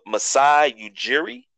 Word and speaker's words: Masai Ujiri: Masai Ujiri.